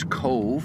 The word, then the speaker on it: Cobh: For those of you who don't know, it's spelled C-O-B-H, because B-H in Ireland is pronounced as a V, or B-H in Irish is pronounced as a V, as in Siobhan.